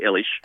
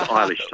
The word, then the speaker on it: Eilish: when you refer to someone as Billy Eilish. No, it's Eilish, Tom.